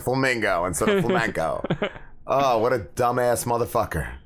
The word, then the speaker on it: flamenco: Wheel of Fortune contestant mispronounces, I can't even say that, Flamin' Co. – That's why I picked it. I think we should send you on Wheel of Fortune and you would do a lot of great jackass. – Flamenco.